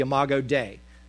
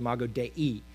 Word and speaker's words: Imago Dei: The Imago, in English, you probably heard it more, the Imago Dei. In Latin, it's actually pronounced Imago Dei.